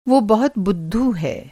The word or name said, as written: बुद्धू